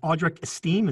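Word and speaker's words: Audric Estime: So we got a lot of questions about Audric Esteem. Is that how you pronounce this kid's name? – I believe so, yes.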